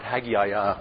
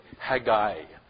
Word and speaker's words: Haggai: Not Haggai or whatever, but Haggai is the way it's pronounced in Hebrew.